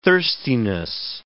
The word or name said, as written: thirstiness